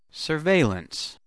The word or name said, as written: surveillance